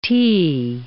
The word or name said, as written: t